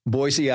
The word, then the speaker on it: Boise: And I went from, Yuma, Arizona to Boise, Idaho. Mm-hmm. Boise, by the way, is the proper pronunciation. – I understand this from the natives.